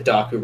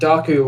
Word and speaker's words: Dokku: Docker? How do you spell that? – D-O-K-K-U. It's like Heroku. – Oh. – Like the K-U on the end. It's like Docker and Heroku together, yeah.